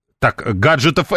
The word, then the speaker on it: гаджетов: гаджетов Apple, вот так правильно говорить.